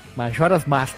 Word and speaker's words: Majora's Mask: Saiu Legend of Zelda Majora's Mask. – Majora's Mask, a pronúncia correta, hein? – Vagrant Story também saiu.